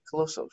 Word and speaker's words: Coloso: Is that how you say your last name? Coloso? – Coloso, yes.